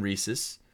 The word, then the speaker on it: Reese's: Again, Reese's.